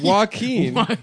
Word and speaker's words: Joaquin: How do you say his name? Joaquin? Mm-hmm. – Joaquin Noah? – Joaquin Phoenix? – Do you really not know? – It's Joaquin. – That's right.